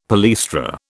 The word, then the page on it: palaestra: British English